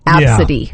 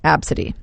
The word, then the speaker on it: Abcde: The name ABCDE pronounced Absidy. – Yeah. I have never got that. You name your kid ABCDE. Absidy is how you say that? – Absidy.